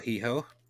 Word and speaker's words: Hijo: I also have one uh, from El Hijo, uh, which should be El El Hijo, I suppose. You don't pronounce the H in Spanish.